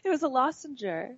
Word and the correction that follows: lozenge: I think it's just a lozenge. – I call it really? lozenger. You do? I do, but it's, it's a lozenge, but I call it a lozenger.